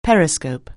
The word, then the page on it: periscope: anglais